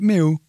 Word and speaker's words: Millwall: Millwall, is that how you pronounce it? – No, you pronounce it Mill.